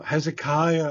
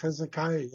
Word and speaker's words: Hezekiah: Well, Hezekiah, I'm sorry, folks. Hezekiah, yes, it just came to me after I mispronounced it.